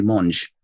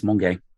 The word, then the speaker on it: Monge: This is a really educational blog post, because up until like 2 weeks ago, I thought it was Stephanie Monge. It's not, it's Monge.